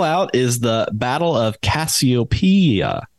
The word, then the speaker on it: Cassiopeia: So the Cassiopeia, I think I'm saying that correctly. – Cassiopeia. Yeah.